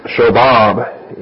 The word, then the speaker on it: Shobab: Under point E, we give you Shobab, S-H-O-W-B-A-B, Shobab. And that actually is a short ah sound on the bob. It's not Shobab. It's Shobab, and if you're going to give it the pronunciation, I won't go back into that.